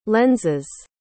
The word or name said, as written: lens's